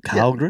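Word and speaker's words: Källgren: Now, the A has an umlaut, so I don't know. Did I get that right? Kallgren? Kallgren? – Yeah, just say it fast.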